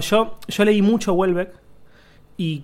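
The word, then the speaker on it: Houellebecq: Entonces lo busqué y eh, se dice Huelbeck. En francés se dice Huelbeck. – Welbeck. – Welbeck o Welbeck, pero creo que es Welbeck.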